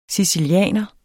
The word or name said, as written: sicilianer